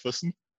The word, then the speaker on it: Füssen: Now, with you being near Boston, being in Worcester, obviously that's a very different area because you came from, excuse my pronunciation, but it's Füssen, Germany, correct? – Yeah, it's pretty close, Füssen.